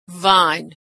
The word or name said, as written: vine